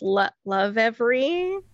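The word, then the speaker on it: Lovevery: So we get those, I hate trying to pronounce this, Love Every. – That's how I would say it.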